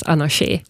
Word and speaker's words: Anarché: Och som uttalas Anarché, vilket då med accent på sista e-et ibland har varit ett problem för vissa.